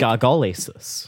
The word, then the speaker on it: gargalesis: But no, I watched a couple of videos and they all pronounced it gargalesis. So okay, that's what we're going to go with.